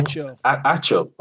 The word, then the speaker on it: Acho: Emmanuel Echo, right? That's how you pronounce his last name? – Acho. – Okay. Emmanuel Acho.